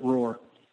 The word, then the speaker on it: Roar: R04R is how we spell it, but we pronounce it Roar.